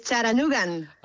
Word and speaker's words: Chattanooga: Es Chattanoogan. – Ah, Chattanoogan, vale, vale, vale. ¿Y el nombre de la ciudad cómo se pronuncia bien, bien? – Se dice Chattanooga, con la T como muy suave, sí.